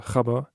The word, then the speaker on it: Gabber: Denn es wird bis zu 190 BPM hochgehen bei Gabber. Oder wie die richtige Aussprache wäre, Chabber. Denn das ist ein Wort aus einem niederländischen Dialekt.